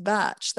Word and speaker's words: Bach: It's a Welsh name and it's meant to be pronounced Batch. That's how the Welsh say it.